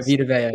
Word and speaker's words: Vita Vea: Maybe not to that extent, but I'm going to pronounce his name wrong, but like Vita Vea. For the Bucs. – Yeah, Vita Vea, yeah.